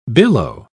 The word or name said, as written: billow